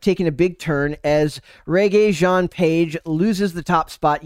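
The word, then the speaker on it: Regé-Jean: It looks like Reggie to me, but obviously it's Regé. It's obviously not.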